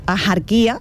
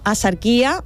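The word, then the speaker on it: Axarquía: Pues yo no conozco a nadie que pronuncie ajarquía, ni nosotras mismas pronunciamos ajarquía, sino asarquía o ajarquía, manteniendo ese hilo que conecta la comarca con su pasado andalusí.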